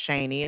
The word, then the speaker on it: Shaney: My name is Shaney, and it's spelled S-H-A-N-E-Y.